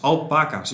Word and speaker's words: alpaca's: hoe spreek je dat uit? Alpaca's, volgens mij. Met de nadruk op de pa.